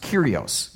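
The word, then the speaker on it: Kyrios (κύριος): The Greek word that's translated Lord is pronounced Kyrios,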